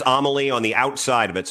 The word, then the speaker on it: Amalie: They may correct you because I think it's pronounced Amalie. – No, I did. I asked that and they said Amalie.